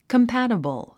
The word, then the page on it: compatible: kəmpǽtəbl; コンパタブル